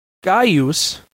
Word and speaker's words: Gaius: In classical Latin, it was pronounced Gaius Iulius Caesar. Note that the first name, like the second, is properly pronounced in three syllables, not two.